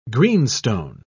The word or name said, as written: greenstone